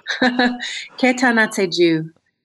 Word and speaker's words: Ketanatseju: Ketanatseju is about healing together. So natseju means to heal, and ketanatseju means we are healing together.